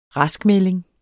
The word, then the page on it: raskmelding: -ˌmεleŋ; -ˌmεlˀeŋ